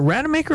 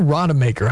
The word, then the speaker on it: Rademaker: Amy Rademaker, she's, is it Rademaker or Rademaker? How do I say that, Amy? – You could say it either way.